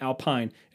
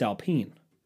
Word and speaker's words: Alpine: It's not Alpine. It's Alpine. It's not like the speaker's Alpine. It's Alpine. Race car.